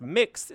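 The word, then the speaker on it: Mx: Everyone is not a Mr. or Mrs. The prefix MS. X, pronounced mix, is often used for guests who are non-binary.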